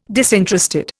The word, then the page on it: disinterested: Indian